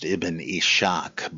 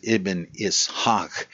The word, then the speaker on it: Ibn Ishaq: often mispronounced Ibn Ishaq, but that's not right. It's Ibn Ishaq.